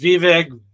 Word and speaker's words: Vivek: Everybody says Vivek, a lot of different names. But he finally came out and really tried to help us. So what he said is, it's V, and it rhymes with cake.